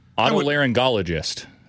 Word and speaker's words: Otolaryngologist: It's O-T-L-Y. – Otolaryngologist. – Do you think you have to pronounce it to be one? – Otolaryngologist. There we go. – Otolaryngologist. – Mm-hmm. Common spelling. – Is that A-U-T-O or O-T-T-O? – O-T-O.